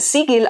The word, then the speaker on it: Sigil: Und hier wurde es jetzt immer Sigil ausgesprochen. – Ja, das ist auch die korrekte Aussprache.